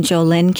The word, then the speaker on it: Jolyn: If you go to Amazon and you look for Far Above Rubies and my name, Jolyn Canty, and it's spelled J-O-L-Y-N, and my last name is C-A-N-T-Y, like candy but with a T, not a D, and you'll find it.